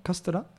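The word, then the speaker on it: Castra (カステラ): U otras palabras como la palabra Castilla, allí lo llaman Castra, que es una manera de pronunciar la palabra Castilla. Seguramente la gente de la península que fue fuera del sur y dije algo así como Castilla.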